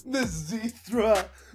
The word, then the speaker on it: Mizithra: So, Mizithra rhymes with urethra.